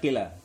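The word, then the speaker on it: kila (قلى): Yang kila, ini bacanya kila. Qaf lam alif.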